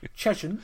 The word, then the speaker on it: Cheshunt: They play at Cheshunt. I don't know if I'm saying that right. Am I saying that right? – Yeah, Cheshunt. Cheshunt. – Yeah, Cheshunt. Yeah.